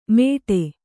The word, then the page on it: ಮೇಟೆ: Kannada